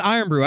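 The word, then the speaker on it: Irn-Bru: It's pronounced iron brew. I don't know why you insist on calling it iron brew. It's iron brew.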